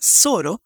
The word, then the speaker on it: choro: Nació en 2012 el deseo de difundir el lloro, que se pronuncia Zoro, en Italia y Europa para dar a todos la oportunidad de apreciar esta sofisticada música instrumental brasileña, buena para tocar y escuchar.